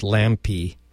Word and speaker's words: Lampe: And that's letter D, letter A, and then Lampe, Lampe. Actually, there's only one E, so Lampe, but it's pronounced Lampe Construction.